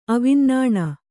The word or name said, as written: ಅವಿನ್ನಾಣ